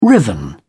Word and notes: riven: British English

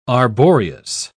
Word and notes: arboreous: British English

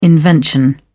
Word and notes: invention: British English

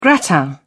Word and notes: gratin: British English

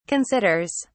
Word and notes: considers: British English

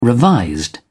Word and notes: revised: British English

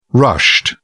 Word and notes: rushed: British English

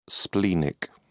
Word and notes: splenic: British English

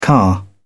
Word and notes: car: British English

